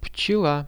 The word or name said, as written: pchela